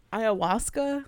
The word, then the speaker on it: ayahuasca: Gives him like ayahuasca.